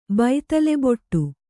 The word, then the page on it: ಬೈತಲೆಬೊಟ್ಟು: Kannada